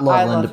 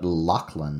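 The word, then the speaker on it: Lockland: Not Loveland, but Lochland. – Lochland, not Loveland. Lochland. – Lochland, yeah.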